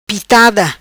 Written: Pitada

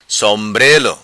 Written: Sombrelo

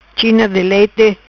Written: China di lete

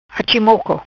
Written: Achi moco

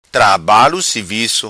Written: Trabalo Sivico

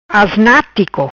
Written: Asnatico

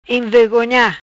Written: Envegonha

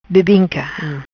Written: Bebinca

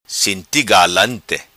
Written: Sinti Galante

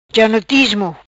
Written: Janotismo

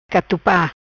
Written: Catupa

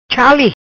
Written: Chali

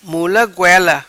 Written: Mula Goela

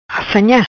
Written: Assanha

